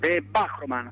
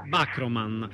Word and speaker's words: Bachroman: bene, con noi regista insieme a Massimiliano del documentario Bachroman, è giusto la pronuncia? – Bachroman, con l'accento sulla A. – Bachroman.